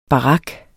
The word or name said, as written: barak